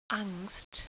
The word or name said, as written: Angst